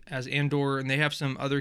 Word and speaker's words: Andor: Andor. I'm like, dude, it's just Andor.